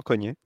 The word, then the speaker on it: Cogne: c'est dimanche à Cogne. on prononce comme ça, Ou Cogne non, non, Cogné.